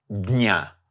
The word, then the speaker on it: jña: Jña is very difficult to pronounce in Sanskrit because it is a combination of J and N with no vowel in between. So in North India, they tend to try to pronounce that jñāna, for example, or jña. And in West India, they tend to pronounce it jña. And in South India, they do different ways.